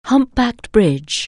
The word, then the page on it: humpbacked bridge: anglais